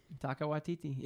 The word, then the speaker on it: Taika Waititi: Number 9 is Jojo Rabbit by Taika Waititi. I'm saying that right? – Taika Waititi.